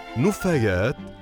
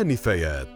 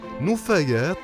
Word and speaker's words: نُفايات: قل ولا تقل مع بطرس موسى على العربية بودكاست كثيرا ما تلفظ كلمة النفايات بكسر النون مع أن الصواب هو لفظها بضم النون فنقول نفايات لا نفايات لأن جمع نفاية يكون على وزن فعالة مثل قمامة إذا قل نفايات بضم النون ولا تقل نفايات بكسر النون